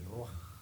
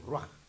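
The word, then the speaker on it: ruaj (רוח): En hebreo, la voy a pronunciar, el ruaj. Amén. Hebreo, ruaj.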